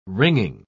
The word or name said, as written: ringing